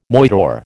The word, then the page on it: moidore: American English